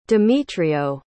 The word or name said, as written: demetrio